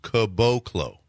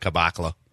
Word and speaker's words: Caboclo: So according to Basketball Reference, it's pronounced Caboclo. – Okay. – Caboclo. I would have never got that. I would have said Caboclo.